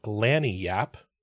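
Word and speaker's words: lagniappe: They pronounce it Lanyap," so kind of a Y in the middle or an E sound in the middle.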